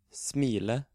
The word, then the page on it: smile: Norwegian